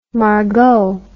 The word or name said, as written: margaux